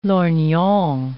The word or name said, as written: lorgnon